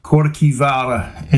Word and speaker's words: Kirkwall: It's actually pronounced Kirkjuvalla in Old Norse.